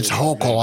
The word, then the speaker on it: Hochul: Yeah, it's Hochul. I had to look it up, but it's Hochul. Yeah. – Rhymes with yokel.